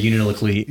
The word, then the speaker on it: Unalakleet: Yeah, I think about it, like, I'm there, and again, I'm older, but when we were up in Unalakleet together, I don't know how to say that. – Yeah, is it just Unalakleet? – I say Unalakleet, but you hear people in Unalakleet say Unalakleet.